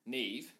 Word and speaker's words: Niamh: Spelt M-I-A-M-H. – Yeah, N-I-A-M-H. Yep. – So like Liam. – How do you Liam. – Like Liam. – with an N. Liam with a silent H on the end.